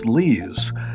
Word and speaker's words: Lees: Hello, my name is Brian Lees. I know it's spelled like lies, but my last name came from the country called Luxembourg, and it's pronounced Lees.